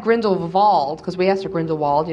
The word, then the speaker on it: Grindelwald: I was wondering what you guys thought about Grindelwald. – Mm-hmm. – And noticed that like the W is pronounced V as in German pronunciation.